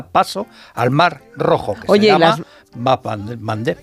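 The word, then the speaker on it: Bab el-Mandeb: Bap el Mandeb. – Bap el Mandeb, esa me la voy a apuntar. – Cuesta un poquito pronunciarlo, Bap por eso Mandeb. En Occidente se dice el Mandeb.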